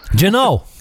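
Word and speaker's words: Jeannot: And then, of course, I can never remember how to say his name. Jeanette? The rookie for the Predators. – Oh, Tanner Janot. – Janot, there you go.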